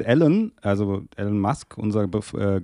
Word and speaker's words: Elon: Also fremde Leute nennen ihn Elon und der sagt dann, das ist okay, aber die Betonung ist Elon.